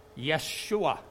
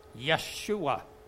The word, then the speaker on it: Yeshua: Because in the Spanish language, somebody who's, you know, J-E-S-U-S is called Jesus. So it's more similar to that than even our pronunciation. But in the Old Testament, in Hebrew, the name would be different, and the name would be Yeshua, Yeshua. And so on the end, it has the A-H sound. And when you have a J-A-H or an A-H sound,